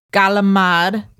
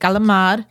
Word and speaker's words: calamari: Then there is squid or calamari, or if you are from New York City, calamad. – That's right. – Right? Calamad. – That's right. Good.